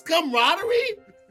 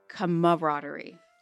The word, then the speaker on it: camaraderie: Technically, you're still saying it wrong because there is another A. It's camaraderie. – So really? – So you're saying camaraderie. – Everybody's been saying it wrong. – You're putting two syllables together.